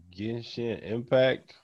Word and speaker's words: Genshin Impact: I've been playing Genshin Impact.